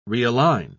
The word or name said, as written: realign